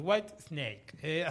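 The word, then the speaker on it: Whitesnake: Me dice, porque si no, después todos lo pronuncian mal. Me dice, te escuchan a vos, dice, lo pronuncian mal. Y claro, es cierto, es White Snake.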